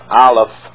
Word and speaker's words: Aleph: It's pronounced Aleph.